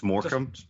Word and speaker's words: Morecambe: You know, and that's an FA Cup match against, is it Morecambe? How do you say it? Did I actually say that right? Or you're muted. Yeah. – No. – It's Morecambe. – Morecambe. Okay.